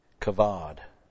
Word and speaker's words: Kavod: BH, not PH. Kavod. And a BH, really, most people pronounce it as a V, but anyway.